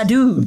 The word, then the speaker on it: Bladud: It's worth saying that his name is spelled Bladud, but it sounds like Bladdered, which is another slang term in Britain for being drunk.